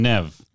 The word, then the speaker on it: Neve: So it's Liev and Neve? Or is it Liev and Nev? – Nev. – Oh, Nev as in Bev, right? – Nev as in Bev.